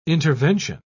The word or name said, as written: intervention